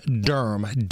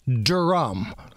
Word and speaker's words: Durm: The guy's name that is no longer with us, the hero that has left us way too early, was John Durm, D-U-R-M. – Right. – Well, sometimes I've heard people pronounce it as Durham. – Oh. – Right? And maybe this is like an Indiana Southern drawl kind of thing, where if you just slow it down, Durm.